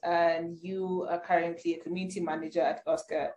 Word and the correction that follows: AltSchool: Yeah, I said AltSchoolAfrica. Sorry, maybe I didn't pronounce it well. AltSchool. – I had Oscar. AltSchool. – Yeah, AltSchool. Basically, alternative school.